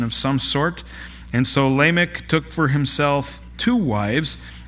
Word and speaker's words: Lamech: Lamech, it would be the English way of pronouncing it.